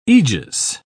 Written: aegis

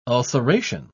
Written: ulceration

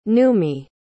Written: nummi

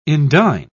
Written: indign

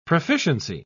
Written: proficiency

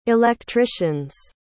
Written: electricians

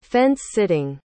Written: fence-sitting

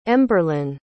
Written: emberlynn

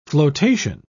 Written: flotation

